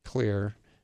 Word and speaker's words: Xlear: X-L-E-A-R, you always put the X. It's not spelled with the C, it's an X, Clear.